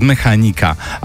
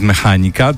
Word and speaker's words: Mechanika: Zła akcentacja. Mechanika. Z Mechanika, a nie z Mechanika. To nie biblioteka.